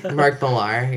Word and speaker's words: Millar: Mark Millar, yeah. – Mark Millar, is that how you say it? – Yeah. – I've never said it out loud. – Miller? – Mark Millar.